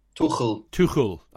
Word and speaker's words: Tuchel: It's Tuchel how you pronounce it, isn't it? – Tuchel.